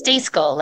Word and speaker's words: Stejskal: And it's Stejskal, like you're telling a dog to stay and the skull around your brain.